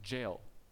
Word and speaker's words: Jael: I love one of my favorite stories in the Old Testament is the story of, I think it's pronounced Jael, J-A-E-L, woman, as they're in this battle with the Assyrians.